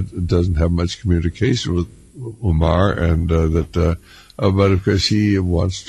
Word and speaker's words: Umar: Umar? – Yes.